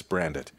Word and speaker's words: Brandit: I get my guests on and I'm like, Catherine Brandit, or Catherine Brant, it's Brandit. Okay.